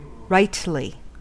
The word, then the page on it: rightly: American English